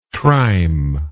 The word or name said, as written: trime